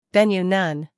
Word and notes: venue: ˈvenjuː

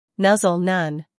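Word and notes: nuzzle: uk ˈnʌzl; en-us ˈnʌzl

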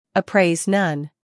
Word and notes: appraise: əˈpreɪz

